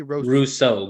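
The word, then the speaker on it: Rousseau: Gregory wrote, how you say that? – Russo. Gregory Russo. – Russo. You spell it so weird, but it's okay. That's how you were raised.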